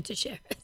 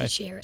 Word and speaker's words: Teixeira: Because I can't roll my R's, so I can't say it like how you're really supposed to say it. But I'll go with your way. – Teixeira. If it's Brazilian, it's Teixeira.